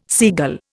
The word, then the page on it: seagull: Indian